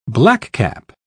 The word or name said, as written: blackcap